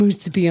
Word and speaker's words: musubi: I know that the word is poke, but, you know, they're not gonna have like spam musubi. Is that how you say it? – Musubi, yeah.